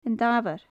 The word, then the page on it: An Damhair: Gaelic